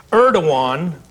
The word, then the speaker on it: Erdogan: Erdogan is how I've been told you pronounce that.